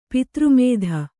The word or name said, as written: ಪಿತೃಮೇಧ